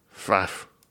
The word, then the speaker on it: Pfaff: Pfaff. – I work with a Pfaff, spelled the same way. P-F-A-F-F. I enjoy that aesthetically. I enjoy saying it. I like that there's a silent P.